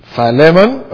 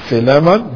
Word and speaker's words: Philemon: Philemon or Philemon, depending on how you choose to pronounce it.